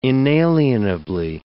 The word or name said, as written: inalienably